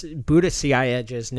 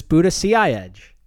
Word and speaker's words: Buttigieg: Yeah, I was just about to say, I can confirm that is how you pronounce Buttigieg's name. – Nailed it. – Which is Buttigieg.